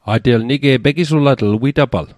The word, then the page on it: pegisulatl: Mi'gmaq